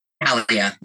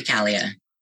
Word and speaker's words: Calia: Calia, like kind of like California. – Okay, Calia. – Kelly Calia.